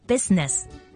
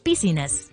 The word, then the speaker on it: business: 早两日讲过喺英文生字里面，有啲字有啲音节系唔需要读出声嘅，就好似之前提过嘅 business，千祈唔好将佢读成 business。